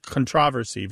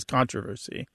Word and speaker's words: controversy: It's also words like applicable becoming applicable, formidable becoming formidable, And the famous one, which actually has a UK-US divide, although usually there is no US-UK divide. It's just a change thing. It's controversy versus controversy.